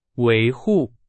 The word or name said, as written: 维护